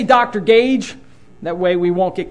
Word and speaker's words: Gouge: His name is Dr. I was told by folks in Greenville that you should pronounce it Gage, Dr. Gage, even though I'm looking at it, it looks like it should be pronounced gouge.